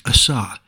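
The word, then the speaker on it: asa (עָשָׂה): The word for created is pronounced bara, and means made from nothing, something brand new, whereas the word for made is pronounced asa, and means not new, but remade or made from pre-existing materials.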